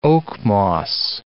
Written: oakmoss

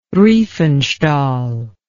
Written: riefenstahl

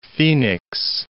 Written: Phenix City

